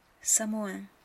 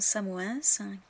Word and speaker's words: Samoëns: Beaucoup de Français se trompent et prononcent ces noms de villes de travers en disant Samoëns, Cassis, Chamonix, Avoriaz. Mais en fait, les gens qui vivent dans ces régions-là, dans ces villes-là, ne prononcent pas la lettre finale.